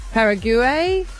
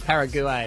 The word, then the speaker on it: Paraguay: Paraguay. – Paraguay. – How the locals pronounce it.